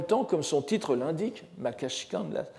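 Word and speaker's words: makashikan (摩訶止観): Nous n'avons pas manqué de rappeler combien ce traité, la somme de quiétude et de contemplation, le makashikan euh, en prononciation japonaise, Guan en chinois,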